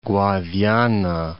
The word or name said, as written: Guadiana